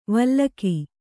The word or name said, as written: ವಿಲೋಕ